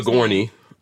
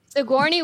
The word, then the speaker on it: Sigourney: Sojourney Weaver. I'm probably mispronouncing her first name. Sigourney. – Sigourney Weaver. Okay.